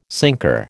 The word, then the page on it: sinker: American English